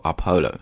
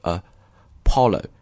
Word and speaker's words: Apollo: 啊。第一个不要读 Apollo, 啊，应该读的是因为呢它在那个重音是往后的，是 Apollo, 然后后面的这个读的是这个 O 这个音，然后最后才是 O 这个音，所以是 Apollo 11,、啊、读的时候要注意这一点就行了。